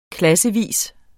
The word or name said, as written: klassevis